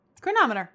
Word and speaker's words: chronometer: Chronometer. – Mm-hmm. Chronometer.